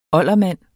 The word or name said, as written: oldermand